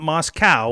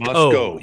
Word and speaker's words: Moscow: Yeah, not Moscow like Russia. It's Moscow. – Moscow.